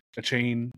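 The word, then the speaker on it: Achane: He said he wants to pronounce A-Chan or something. He was on the... – Silent E? Okay. – Yeah, he was on the socials today saying that it's supposed to be pronounced A-Chain.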